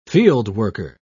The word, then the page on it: fieldworker: British English